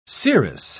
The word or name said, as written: seeress